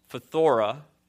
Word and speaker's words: phthora: And the word for corruption here in the original language, it's actually hard to pronounce. It's phthora.